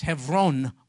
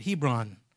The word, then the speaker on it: Hebron: He got a city in that neck of the woods called, in Hebrew, it's pronounced Hebron. We call it Hebron.